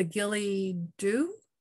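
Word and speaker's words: Ghillie Dhu: The Gilly-Doo? – That's right. That's how I would say.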